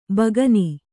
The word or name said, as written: ಬಕ್ಕನೆ